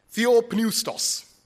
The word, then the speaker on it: Theopneustos: Theopneustos.